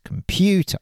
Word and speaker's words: computer: She gets the stress right with the stress computer. On the second syllable. She says computer.